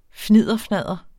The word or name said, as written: fnidderfnadder